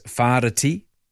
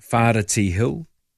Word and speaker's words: Whareti: The Big Hill in Palmerston North in the Manawatu region is known as Whareti. I don't know how many people would say it like that, but that's the correct pronunciation, Whareti Hill.